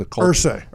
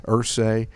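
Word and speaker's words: Irsay: Ursay. – Ursay, 2014, charges of driving while intoxicated, possession of controlled substances after they found some prescription drugs and cash.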